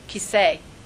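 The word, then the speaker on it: Kiseh (כיסא): And Kes means throne, but that's not really how you say it. Does anybody know what it's really called? How do you say chair in Hebrew? – Kiseh. – Okay? Al Kes Yah is a shortened form of Kiseh.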